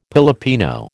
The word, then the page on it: Pilipino: American English